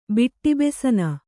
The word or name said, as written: ಬಿಟ್ಟಿಬೆಸನ